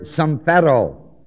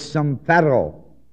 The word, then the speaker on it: sumphero: The word expedient is the Greek word sumphero, S-U-M-P-H-E-R, long O. Expedient is sumphero, S-U-M-P-H-E-R, long O.